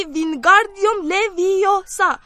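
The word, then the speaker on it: Wingardium Leviosa: وینگاردیوم لویوسا کریم صدای هرسی جون را شنید که با اوقات تلخه میگفت داری اشتباه تلفظ میکنی باید بگی وینگاردیوم لویوسا گارش رو بهتر و کشیده تر تلفظ کن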